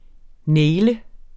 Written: naile

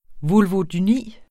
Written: vulvodyni

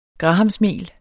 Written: grahamsmel

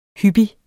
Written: hyppig

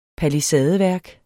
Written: palisadeværk

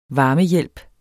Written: varmehjælp

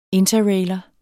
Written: interrailer

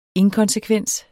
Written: inkonsekvens

